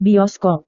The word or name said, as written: bioskop